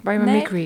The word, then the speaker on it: biomimicry: Maar het is biomimicry of biomimicry? – Nee, biomimicry.